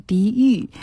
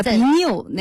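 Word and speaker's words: 鼻衄: 但是也有另外一种，就是一个血字旁右边加一个丑字那个字，应该读成鼻郁。 – 鼻郁，鼻错了，叫鼻扭，那个鼻扭。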